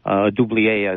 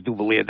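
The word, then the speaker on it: Dubilier: Dublier, as the mayor pronounced it, which I think that's the correct pronunciation. – Could be, it was always Dublier down here.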